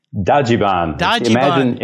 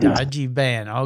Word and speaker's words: Dajiban: Flying to Japan for a deep dive into the land's offbeat Dajiban? Am I saying that right? – Dajiban. Imagine a Japanese person who loves vans saying the word Dajiban. – Dajiban.